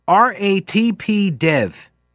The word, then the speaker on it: RATP Dev: Another part of the plan relies on third-party contractor RATP Dev, is how they pronounce it, I believe. The company that employs Katz's bus operators and mechanics, RATP Dev, contract negotiations with Smart Union.